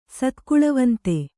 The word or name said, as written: ಸತ್ಕುಳವಂತ